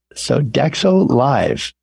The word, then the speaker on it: Sodexo: Hollywood Bowl Food and Wine is presented by the Los Angeles Philharmonic in partnership with the Luke Group and Sodexo Live. Now, did I pronounce Sodexo Live right? – You did. That is it.